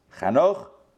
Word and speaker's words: Chanoch: Jacob's firstborn was Reuben, and the sons of Reuben were Hanoch and Falu, Chetzron, and Charmi. These are, I'm giving you the Hebrew pronunciations, these are the sons of Reuben. He has four sons Chanoch, Falu, Chetzron, and Charmi.